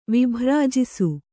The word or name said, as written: ವಿಭ್ರಾಜಿಸು